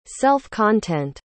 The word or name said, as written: self-content